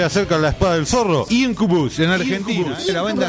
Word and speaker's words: Incubus: Incubus. Así se pronuncia.